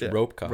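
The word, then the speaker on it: rope caulk: C-A-U-L. – Yes, I know, not C-O-C-K. – Yeah. – No, I'm pronouncing it wrong. But That's you're saying it right. It's ropecock.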